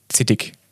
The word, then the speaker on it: Zittig: Wie würde jetzt der Badenser oder der Alemanne das aussprechen, das Wort Zeitung? Also ja. – der Schwabe sagt Zeitung und. Viel schöner. Zittig.